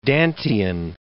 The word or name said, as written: Dantean